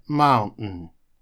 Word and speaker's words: mountain: То есть они, по сути, выкидывают звуки NT, потому что в современном английском букве T война объявлена, и после U делают небольшую такую паузу с придыханием, после чего говорят N. Получается mountain, mountain. Вот если у вас так не получится, можете говорить mountain, это тоже правильное произношение, хотя в реальной жизни вы услышите mountain. Следующий предлог – это через.